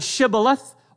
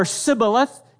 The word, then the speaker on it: shibboleth: And those who are killed are betrayed by their accents with the word shibboleth or sibboleth. You would tell on yourself as to where you were from. And if you mispronounced the word, Jephthah said, "Kill them."